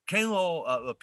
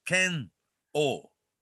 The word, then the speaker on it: Ken-O: Ken-O, people in English-speaking world, you know, it's hard to pronounce. They always say Ken-O, right? – Mm-hmm. – It's Ken-O.